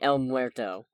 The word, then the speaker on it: El Muerto: If they're going to keep one of the movies coming up, Hell or High Water, I want it to be El Muerte, just to see what the heck they're going to do with it. I'm sorry, El Muerto. I was mispronouncing.